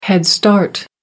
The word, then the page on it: head start: American English